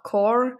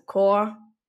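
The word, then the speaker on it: cor: Ist ja Symbol für Liebe, Leben und auf Lateinisch heißt es auch Core, also Core, nicht englisch ausgesprochen.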